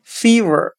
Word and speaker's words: fever: 我们用谐音法来记忆这个单词 fever，它的读音很像沸我沸腾的沸，我自己的我。